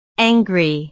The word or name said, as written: angry